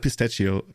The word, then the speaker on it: pistachio: Enchilato, pistachio. Pistachio? Pistachio? I don't even know how to pronounce pistachio. – It. Pistachio. Yeah, that's the one. – CH is pronounced like a K in Italy.